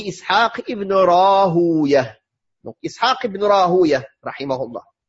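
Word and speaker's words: Rahawayh: Il y a deux manières de le prononcer, parce que c'est d'origine perse. Ibn Rahouya, comme Ibn Majah, Sibawi, Sibouya, tous ces prénoms-là, c'est d'origine perse. Donc, ou bien on le prononce comme à son origine, cette manière-là, Rahouya, ça c'est la prononciation perse. Ou bien on l'arabise, comme ça a été fait, Sibawi au lieu de Sibouya, et Rahouya au lieu de Rahouya. Quand on dit Rahaway, c'est la manière arabisée de son nom.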